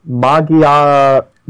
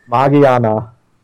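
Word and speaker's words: Magearna: And we got our representative, our first legendary that appeared for Gen 7 is coming to the TCG, Magirna. I said that right? – Yes. – It's pronounced so. Japanese Magiana. – Such a show-off. – Magiana. So it's Magiana.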